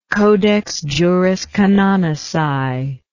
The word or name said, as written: Codex Juris Canonici